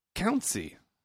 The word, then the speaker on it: Kountze: Oh, the town is Kuntsi. – How do you spell that? – I'm being generous with this pronunciation. K-O-U-N-T-Z-E. Kuntsi.